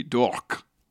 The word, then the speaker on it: Dourque: But Dork is spelt like... Dork like is spelt Hawk? Like a French name. D-O-U-R-Q-U-E. Dork. – Wow, like talk.